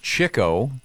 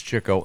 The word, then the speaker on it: Chico: It's pronounced Chico. Really? – I've never heard that Chico one. – But it's Chico.